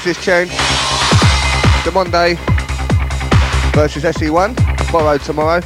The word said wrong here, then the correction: Monde: The Monde. – That's what I meant, word. However you want to say it, innit? – Obviously, you're saying it wrong. The Monde.